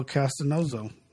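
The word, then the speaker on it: Castonzo: Left tackle, Alonzo Castanozo. – Yep. – If I pronounce that right. – Yes, sir.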